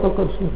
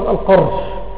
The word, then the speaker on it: قرش: القرش وليس وليس القرش تنطق بالفتح. الصواب القرش فتح القاف.